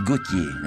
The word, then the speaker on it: Gautier: L'altro è Théophile Gautier, no? Senza L, Gautier, poeta parnassiano.